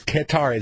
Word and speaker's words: Qatar: Qatar, is that how you pronounce that country? – Yeah.